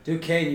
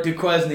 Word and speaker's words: Duquesne: Duquesne, you pronounced it right. The first time I went there, I asked people where Duquesne was. did not go at all. So if you've ever seen the word Duquesne, it's pronounced Duquesne.